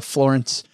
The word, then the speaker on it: Florence: I think it's pronounced, if you've driven down I-75, you know, it's pronounced Florence, y'all.